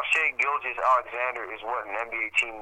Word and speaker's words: Shai Gilgeous-Alexander: See, I'm not even pronouncing his first name right. Oh my god, his name is just giving me aneurysm. Ty Gilgis Alexander. Shay Gilgis Alexander. Shai Gilgeous-Alexander has like everything that Nubakina does the defense and the playmaking ability, but not so much the offense.